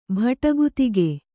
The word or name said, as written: ಭಟಗುತಿಗೆ